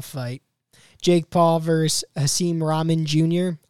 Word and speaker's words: Rahman: Haseem Rahman Jr. Yeah, so there's definitely no K.